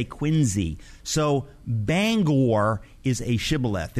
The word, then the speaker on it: Bangor: How do you pronounce Bangor? Is it Bangor, Bangor? – Bangor, Maine. – Yes. – Is pronounced Bangor, not Bangor. Bangor.